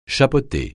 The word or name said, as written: chapeauter